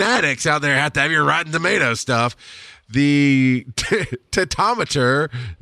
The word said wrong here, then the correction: tomatometer: is tomatometer. There we go. I said it right.